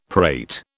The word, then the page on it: prate: British English